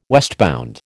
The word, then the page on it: westbound: American English